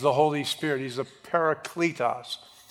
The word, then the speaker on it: parakletos: is the Greek word parakletos, or some pronounce it parakletos,